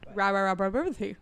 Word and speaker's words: Ramamurthy: Ramamurthy.